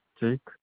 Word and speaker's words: Jake: Jake.